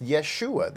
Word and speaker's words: Yeshu: And he was pointing out that the name Yeshu is actually the way in which the Galileans would have pronounced Yeshua. They had a hard time pronouncing Yeshua. I guess, you know, it had kind of like a country accent, maybe. And so they would pronounce it Yeshu.